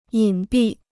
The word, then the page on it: 隐蔽: yǐn bì